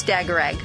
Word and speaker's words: Dagarag: No, you're pretty close, Dagarag.